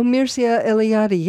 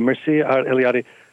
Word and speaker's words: Mircea Eliade: There's a wonderful historian, a Romanian historian of religion, the sacred, and I'm going to mispronounce his name, Mircea Eliade. – Oh, Mircea Eliade, yes. – Thank you, Mircea Eliade, who says that a dwelling contains the world.